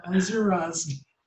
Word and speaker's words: Misuradze: Misuradze.